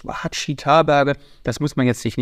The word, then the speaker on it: Ouachita: Es gibt unter anderem da einen 360 Kilometer langen Wanderweg, den Ouachita, diesmal habe ich es richtig ausgesprochen, National Recreation Trail.